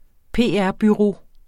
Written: PR-bureau

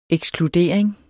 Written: ekskludering

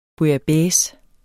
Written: bouillabaisse

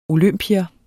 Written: olympier

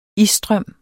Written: isstrøm

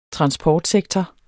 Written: transportsektor

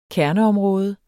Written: kerneområde

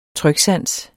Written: tryksans

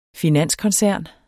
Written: FINANSKONCERN

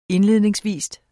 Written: indledningsvis

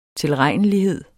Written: tilregnelighed

